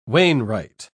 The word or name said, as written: wainwright